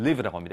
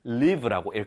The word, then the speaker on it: live: 여기서는 live라고 합니다. 이게 동사일 때, 즉 움직임, 살다라는 뜻일 때는 살다, 거주하다라는 뜻일 때는 live라고 읽고요. 그렇지 않으면은 live, 여기서는 형용사입니다. 형용사로 쓰일 때는 live로 읽습니다.